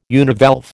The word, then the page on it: univalve: American English